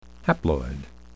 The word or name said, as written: haploid